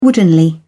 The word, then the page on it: woodenly: British English